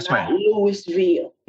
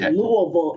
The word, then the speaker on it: Louisville: So I left the Army and went to law school and let me correct your pronunciation. It is Louisville. It is Louisville.